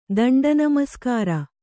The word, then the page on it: ದಂಡನಮಸ್ಕಾರ: Kannada